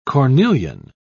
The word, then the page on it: cornelian: British English